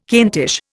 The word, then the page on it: Kentish: Indian